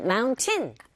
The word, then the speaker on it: mountain: 이때도 역시 T 사운드는 약하게 발음이 돼서 은 사운드, T 사운드 맞물려 있는 경우에 T 사운드가 잘 안 들리거든요. 그래서 우리에게 들리는 소리는 mountain. 틀리진 않지만 원어민의 입에서 자주 등장하는 들리게 되는 소리는 mountain라고 들리니까 여러분들도 그렇게 한번 따라해 보세요.